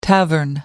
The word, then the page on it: tavern: American English